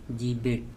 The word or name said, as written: jibeT